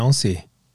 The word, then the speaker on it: Beyoncé: Beyoncé.